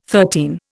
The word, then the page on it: thirteen: Indian